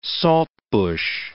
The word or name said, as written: saltbush